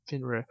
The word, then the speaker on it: Fenrir: But a lot of wolf images in Viking mythology is Fenrir. I probably pronounced the name wrong. – Fenrir.